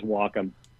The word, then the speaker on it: Wacom: I do a lot of work with those guys, and it is Wacom. It's an amalgamation of two words. It's Wacom, and everybody else pronounces it wrong.